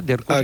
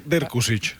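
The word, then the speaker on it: Drkušić: Hi ha el nou fitxatge del central eslové, Banja Dirkusic, no? Em sembla que s'ha de pronunciar Dirkusic. – Exacte, Dirkusic.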